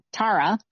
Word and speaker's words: Tara: growing up, well, obviously my parents, they were like, it's Tara, and that's how it's pronounced in Ireland.